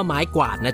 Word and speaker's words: ไม้กวาด: ต้องพูดว่าไม้กวาดนะจ๊ะออกเสียงตัวครวบกล้ำให้ชัดๆว่าไม้กวาดไม่ใช่ไม้ฝาด